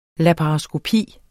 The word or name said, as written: laparoskopi